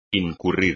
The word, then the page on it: incurrir: espagnol